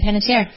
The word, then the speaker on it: Panettiere: Panettiere, is that correct? Is that That is. – Okay. – Hayden Panettiere.